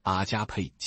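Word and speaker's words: 阿 加 佩 (ἀγάπη): 发音是阿加佩，即神的爱。很多时候你听到阿加佩指的是无条件的爱，在圣经之外文学里，阿加佩是极少用到的一个字。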